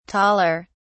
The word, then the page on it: taller: British English